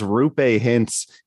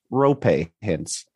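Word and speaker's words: Roope Hintz: Dave, you said his name is Rupe Hintz. His name is not Rupe Hintz, though that is a common belief. It is actually pronounced Rope Hintz, as in rhymes with Cope Hintz.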